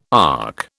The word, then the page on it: ARC: British English